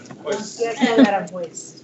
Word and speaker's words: Aranjuez: Aranjuez. – Aranjuez.